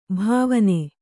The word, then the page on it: ಭಾವನೆ: Kannada